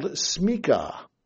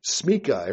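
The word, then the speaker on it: smicha: But the Hebrew word that is underlying this, the Hebrew concept that is underlying this, is something called smika. Smika. Everyone say smika. Smika. Smika. It's spelled S-M-I-C-H-A. S-M-I-C-H-A. S-M-I-C-H-A.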